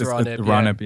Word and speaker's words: Ronip: called Ronip. I think it's pronounced. It's the thing with these, you know, the books here. Hey, you get the words, but it's like, I wonder how it's pronounced. And that's how Brian Froud pronounced it. So I would assume, since he created that character, that is correct. – So it's Ronip.